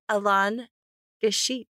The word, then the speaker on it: Alain Guichet: Say hello to Alain Gachet.